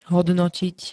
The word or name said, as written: hodnotiť